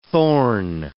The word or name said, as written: thorn